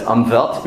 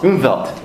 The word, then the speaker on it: Umwelt: For anyone who wasn't here, we talked about this concept of Umwelt, and as Vince taught me, the German way of pronouncing a German word like that is Umwelt, Umwelt, Umwelt.